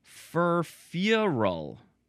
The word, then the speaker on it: furfural: Now, here is that word, furfural. You got to emphasize the first syllable and then a little bit the last syllable. Furfural. Furfural. Okay, there we go. It is F-U-R-F-U-R-A-L.